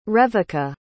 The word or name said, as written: reveka